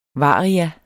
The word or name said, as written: varia